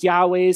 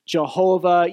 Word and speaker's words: Yahweh: It's God's special name that he gave to his people, sometimes pronounced Yahweh, sometimes pronounced Jehovah.